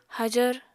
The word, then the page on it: hajvr: Apatani